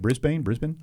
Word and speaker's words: Brisbane: How do you say that? Brisbane. Okay.